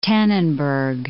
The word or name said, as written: Tannenberg